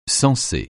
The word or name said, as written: censé